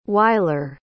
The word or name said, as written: wyler